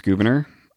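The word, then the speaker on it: Gouverneur: It really is pronounced Governor.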